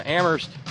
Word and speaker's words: Amherst: Thanks go out on this 29th day of the month to our pal Terry in Amherst, where only the H is silent, as well as K.